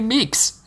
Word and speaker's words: Meeks: Good old Randy Meeks. – Not Randall. – Randy. Meeks, yeah. It rhymes with Meek.